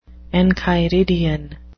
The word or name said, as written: enchiridion